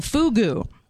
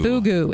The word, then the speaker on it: fugu: It's called fugu. – Fugu. – Fugu. F-U-G-U. Fugu. That's how the Japanese pronounce it.